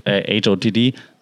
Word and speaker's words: HOTD: And that's the cool thing, actually, also, what I like about Game of Thrones / HOTD. I learned that from YouTube to say it like this. Hot D. I learned to say Hot D.